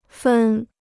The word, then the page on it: 分: fèn